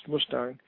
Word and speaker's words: Mustang: which is spelled like the sports car, but pronounced Mustang.